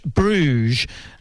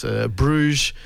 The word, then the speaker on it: Bruges: Bruges. – Yeah, sorry about that. Turned your microphone off already. – It's Bruges, the Carillon, Carillon, the Bells.